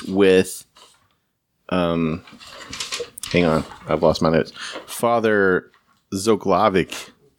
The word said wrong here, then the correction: Zloklavec: Did I pronounce it correctly? – Yeah, Zloklavec. Okay, yeah. I misplaced the L.